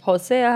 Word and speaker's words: Hosea: Hosea, Isaiah.